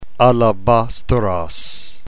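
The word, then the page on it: alabastros: New Testament Greek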